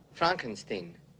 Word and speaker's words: Frankenstein: Frankenstein. – You're putting me on. – No, it's pronounced Frankenstein.